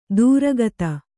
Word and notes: ದೂರಗತ: dūragata

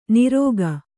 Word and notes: ನೀರೋಗ: nīrōga

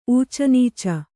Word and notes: ಊಚನೀಚ: ūcanīca; Kannada

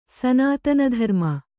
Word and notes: ಸನಾತನಧರ್ಮ: sanātana dharma

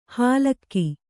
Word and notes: ಹಾಲಕ್ಕಿ: hālakki

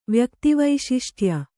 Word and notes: ವ್ಯಕ್ತಿವೈಶಿಷ್ಟ್ಯ: kannada

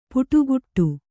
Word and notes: ಪುಟುಗುಟ್ಟು: puṭuguṭṭu

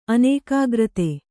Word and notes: ಅನೇಕಾಗ್ರತೆ: kannada

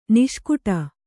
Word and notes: ನಿಷ್ಕುಟಿ: niṣkuṭi

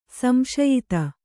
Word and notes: ಸಂಶಯಿತೆ: samśayitae

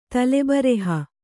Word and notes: ತಲೆಬರೆಹ: kannada

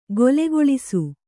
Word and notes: ಗೊಲೆಗೊಳಿಸು: golegoḷisu

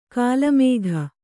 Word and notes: ಕೋಲುಮುಕ್ಕ: kōlu mukka

